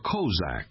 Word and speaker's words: Kozak: K is for Kozak, the proper pronunciation of what non-Ukrainians call Cossack. From a Turkish word for free man or outlaw, the original Ukrainian freedom fighters.